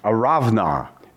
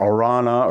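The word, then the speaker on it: Araunah: And the angel of the Lord was by the threshing place of, this is how you say it in Hebrew, Aravna. It looks like Arana or whatever. It's Aravna, which is Hebrew.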